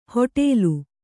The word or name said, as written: ಹೊಟೇಲು